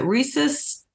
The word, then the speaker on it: rhesus: And I don't know if I'm pronouncing, is it rhesus negative bloodline? How do you pronounce that? Is that right? – That's right. Yes. Yeah, that's right. You pronounced it correctly.